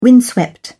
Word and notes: windswept: British English

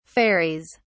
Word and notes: fairies: British English

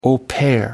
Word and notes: au pair: American English

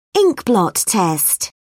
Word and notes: inkblot test: British English

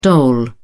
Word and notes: dole: British English

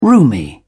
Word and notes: roomy: British English

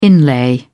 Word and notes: inlay: British English

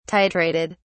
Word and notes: titrated: British English

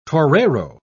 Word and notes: torero: British English

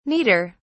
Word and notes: neater: British English

